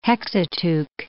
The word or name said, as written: Hexateuch